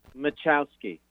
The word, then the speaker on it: Machowski: Before we start, would you mind pronouncing your last name for our listeners? Machowski.